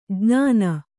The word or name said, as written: ಜ್ಞಾನಿ